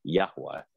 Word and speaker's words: Yahweh: it's Yahweh. It's the Aramaic ancient language. It's pronounced Yahweh, like a breath.